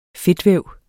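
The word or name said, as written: Fedtvæv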